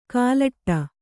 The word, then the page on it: ಕಲ್ಲೆಡೆ: Kannada